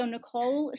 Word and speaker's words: Nycole: So Nycole is spelled with a Y, not an I. So N-Y-C-O-L-E.